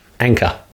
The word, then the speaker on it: Anka: Oh, okay. That's okay, can you say Ank? – Ank. – Ah. Anchor. – Anchor. – Perfect. You have just pronounced anchor as an Australian.